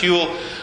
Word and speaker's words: Whewell: Okay, and it gets coined by one of the founders of the British Association for the Advancement of Science, William Whewell. This is pronounced Whewell, and he was the master of Trinity College, Cambridge.